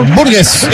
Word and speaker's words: Burgess: Y gana Estados Unidos, Burgess, así se pronuncia, ¿no? – Burgess, exactamente, Cameron Burgess, el stopper a la derecha en esa línea de tres que tiene el equipo australiano.